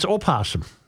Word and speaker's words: opossum: How come when you look it up, it's spelled O-P? – Yeah. – That's all you can go to the Google and just spell it P. – Oh, a possum? – Yeah, but it's opossum. – Yeah, but everybody just says possum.